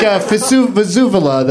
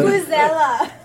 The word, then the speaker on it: vuvuzela: It's like Vesuvula, the horn at the... – Vuvuzela.